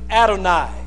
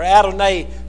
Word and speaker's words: Adonai: And he used the word Adonai. Or Adonai, some would pronounce it.